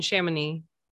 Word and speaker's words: Chamonix: I was staying in Chamonix.